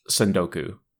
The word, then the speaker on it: tsundoku: It's called tsundoku with a silent T.